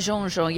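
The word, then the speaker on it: Jeanjean: Am I saying that correctly? Leolia Jean-Jean, yes.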